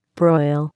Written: broil

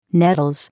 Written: nettles

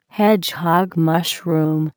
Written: hedgehog mushroom

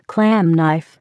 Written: clam knife

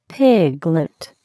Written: piglet

